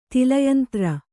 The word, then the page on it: ತೈಲಯಂತ್ರ: taila yantra